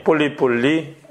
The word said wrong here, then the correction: 폴리포니: 발음이 폴리포니.